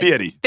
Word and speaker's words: Fieri: Fieri.